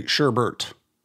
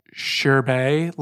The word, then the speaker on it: sherbet: If you look it up, if you look it up online, nowhere in the world does it say sherbert. – There is no second R. There's no Bert.